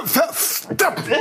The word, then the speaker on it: Verstappen: Verstappen! Hat den Namen Verstappen richtig ausgesprochen.